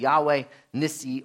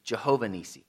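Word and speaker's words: Yahweh Nissi: The name is Yahweh Nisi, or in the older pronunciation, Jehovah Nisi.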